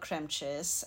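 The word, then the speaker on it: Cremechiss: The podcast is called Kremchis, and it's basically spell it. – It's a weird spelling. It's Krem, like C-R-E-M-E, and then Chiss, C-H-I-S-S. It's basically the mispronunciation of cream cheese.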